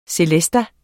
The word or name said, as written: celeste